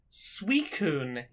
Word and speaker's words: Suicune: The water-based legendary dog from the second gen of Pokemon is called Suicune, is its official pronunciation. In my head, I read it for decades as Suicune.